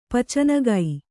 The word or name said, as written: ಪಚನಗೈ